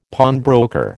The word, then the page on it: pawnbroker: American English